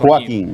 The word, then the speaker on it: Joaquin: Yeah, it's Joaquin. – Joaquin.